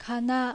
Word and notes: Kana: Japanese

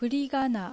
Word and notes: Furigana: Japanese

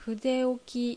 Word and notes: Fudeoki: Japanese

